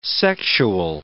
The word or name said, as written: sexual